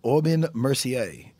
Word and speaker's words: Aubin Mercier: Aubin Mercier, yeah. – Aubin Mercier.